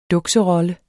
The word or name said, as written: duks